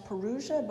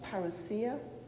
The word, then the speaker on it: parousia: You might have heard it pronounced parousia, but we're going to say parousia.